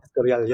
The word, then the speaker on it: Escorial: Escorial. – Escorial.